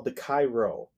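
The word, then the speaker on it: Chi Rho: And the symbol that Christ reportedly showed him in his dream is called the Cairo.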